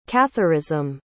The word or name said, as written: catharism